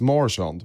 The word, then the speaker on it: Marchand: Jag har fått lära mig att det uttalas Marchand, vilket är väldigt jobbigt att lära sig om och säga.